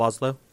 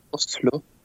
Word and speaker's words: Oslo: There's like two ways to say it. – Oslo. – Where I come from, it's more like Oslo. – But locals in Oslo say it like Oslo. – Oslo. – So it's like, I don't know, it's like a dialect thing.